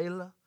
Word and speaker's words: Isla: And my oldest daughter is named Isla, which my supervisor, who was deeply, deeply Scottish, when he pronounced Isla, it was beautiful. It was Isla. It's this beautiful Scottish Baroque.